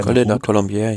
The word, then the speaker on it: Col de la Colombière: Also vor allen Dingen ist vom letzten Berg, vom Col de Colombière, wenn ich ihn so richtig ausgesprochen habe, Bière. Col de la Colombière, ja.